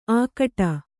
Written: ಆಕಟ

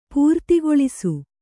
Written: ಪೂರ್ತಿಗೊಳಿಸು

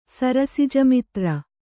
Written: ಸರಸಿಜಮಿತ್ರ

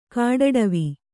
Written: ಕಟ್ಟಡವಿ

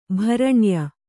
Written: ಭರಣ್ಯ

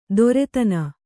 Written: ದೊರೆತನ

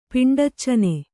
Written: ಪಿಂಡಚ್ಚನೆ

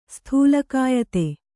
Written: ಸ್ಥೂಲಕಾಯತೆ

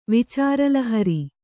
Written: ವಿಚಾರಲಹರಿ

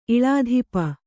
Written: ಇಳಾಧಿಪ

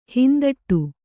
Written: ಹಿಂದಡಿ

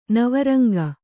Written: ನವರಂಗ

